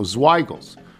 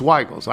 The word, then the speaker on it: Zweigle's: Zweigle's, the creator of the White Hot, Hofmann who, should be said with a long I sound. Okay, rhyming with higher high. So Zweigels. It's Zweigels. I don't know. It's German. When you say Zweigels, everybody in the Finger Lakes region winces.